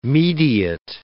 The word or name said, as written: mediate